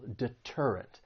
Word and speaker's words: deterrent: This word has three syllables. The stress is on that second syllable, deterrent.